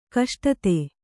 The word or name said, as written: ಕಷ್ಟತೆ